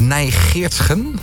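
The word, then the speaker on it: Nij Geertgen: Medisch directeur van het Centrum voor Vruchtbaarheid Nijgeertsgen. Zeg ik dat goed? – Ja, dat zeg je heel goed.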